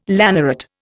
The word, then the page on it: lanneret: Indian